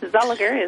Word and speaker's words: Zulliger: Well, it's probably with your own accent. That's correct. Zolliger is how it's typically.